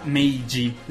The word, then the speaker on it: Meiji: Adesso, io non so se si pronuncia, mm, pronuncia Meiji l'era, perché J-I si pronuncia Alessio? G. G. Sì.